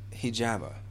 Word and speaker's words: hijama: So the hijama, mm-hmm. – am I saying that right? – Mm-hmm. Hijama. Okay.